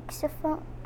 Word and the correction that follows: xylophone: The xylophone? – Yeah.